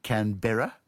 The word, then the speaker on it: Canberra: Then in that case, do you say Canberra or Canberra? – I say Canberra. – Yeah, so do I. But the Americans say Canberra for some reason.